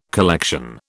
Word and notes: collection: British English

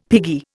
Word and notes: piggy: Indian

